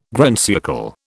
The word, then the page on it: grand siècle: British English